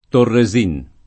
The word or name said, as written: Torresin